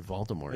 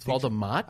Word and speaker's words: Voldemort: It's Voldemort? – I think it's like you don't pronounce the R.